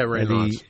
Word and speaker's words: Raynaud's: Yeah, Raynaud's.